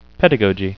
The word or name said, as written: pedagogy